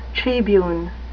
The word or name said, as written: tribune